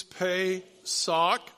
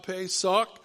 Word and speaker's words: Pesach: Pesach. One more time, Pesach. How do you learn to pronounce it? Well, you paid a good price for a sock.